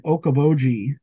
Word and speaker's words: Okoboji: I think you were referring to the Higgins Museum in Okoboji. I think that's how you say it. – Bingo.